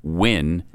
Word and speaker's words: Nguyen: If you wouldn't have known how to pronounce N-G-U-Y-E-N as Wynn, right.